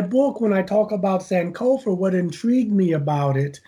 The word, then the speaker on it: Sankofa: And yes, I just Sankofa.